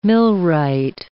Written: millwright

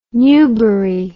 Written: newbury

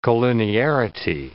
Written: collinearity

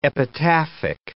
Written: epitaphic

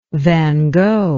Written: van gogh

